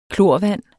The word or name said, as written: klorvand